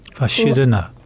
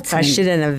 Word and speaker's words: verschiedene: How do you say various? – Verschiedene. – Very good. – That's a hard one. – Yes. – No, Verschiedene. That's easy. – Verschiedene.